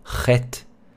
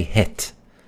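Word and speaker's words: Chet (חית): If you have a tough time saying Ch, so you can say Het. Probably better than Chet. Sometimes it's written CH, but it's better, it's closer to pronounce it Het, like with an H if you can't say the Ch, Chet.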